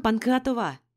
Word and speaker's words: Панкратова: «Мадам Панкратова!» С ударением на последний слог вдруг обратился ко мне ректор.